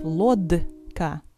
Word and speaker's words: лодка: Notice that the consonant D is actually pronounced voiceless, T. Instead of лодка, we'll say лодка.